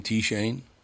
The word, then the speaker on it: TShane: Oh, you know, I should have asked you right up front, do you pronounce your name TShane? – Yes, sir. – I just wanted to make sure, because I've seen it spelled that way a lot of times. They don't pronounce the T, it's just Shane.